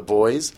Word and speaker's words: Buoys: Timothy was a song recorded by, I believe it's pronounced The Buoys. It's spelt like buoy. – Boy? – Buoys. – Like in the water?